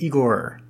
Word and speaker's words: Igor: That would be a mispronunciation, but yes, it is Igor.